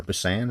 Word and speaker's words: Busan: Train to Busan, or Busan, I think it's pronounced Busan.